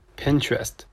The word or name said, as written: Pinterest